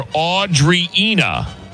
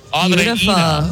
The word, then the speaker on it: Andreina: Okay, now this person's name is spelled like Adriana, but her phonetics are Audrina. Is that right? Are you Audrina? Is that right? – It's Andreina. – Andreina. – Oh, close.